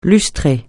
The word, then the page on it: lustrer: francais